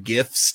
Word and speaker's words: GIF: One of my favorite GIFs to use, and it's pronounced GIF, when I respond to somebody and they tell me something amazing over text.